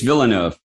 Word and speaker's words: Villeneuve: Villeneuve. I think it's Villeneuve.